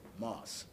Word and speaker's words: Moss: Moss, M-O-S-S.